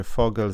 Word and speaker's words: Vogel: E, tutaj też jest kącik językowy, bo Heini też powiedział, że Vogel czyta się Vogel z niemieckiego ptak, to wiem, pamiętam.